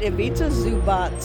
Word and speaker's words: Ivica Zubac: I pronounce it Ivica Zubac. – Zubac. – Like Zubac. It's like TTZ at the end.